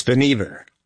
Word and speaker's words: Vannevar: and it's Vannevar Bush or Vannevar Bush. You can tell us how to pronounce it here. – It's Van Ever. – It's Van Ever. – But everybody called him Van. I guess we everybody can. That made it a lot easier. I was putting the accent on the wrong syllable there.